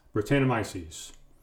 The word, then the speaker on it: Brettanomyces: How do you say that word? – Britannomyces. – Britannomyces. – Yeah.